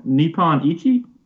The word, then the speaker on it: Nippon Ichi: From Nippon Ichi? – Yes, you got it.